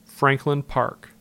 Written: Franklin Park

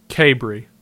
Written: Cabery

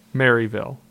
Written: Maryville

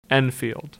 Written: Enfield

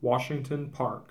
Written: Washington Park

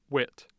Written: Witt

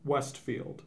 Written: Westfield

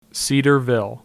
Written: Cedarville

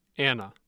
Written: anna

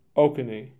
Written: Oconee

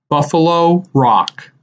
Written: Buffalo Rock

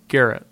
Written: Garrett

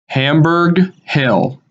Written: Hamburg Hill

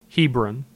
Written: Hebron

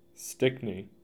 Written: Stickney

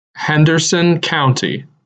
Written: Henderson County

